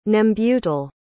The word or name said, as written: nembutal